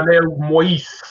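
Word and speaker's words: Jovenel Moïse: Am I saying it right? – Juvenal Moïse. – Moïse. – Moïse. Brother, for what I understand Moïse.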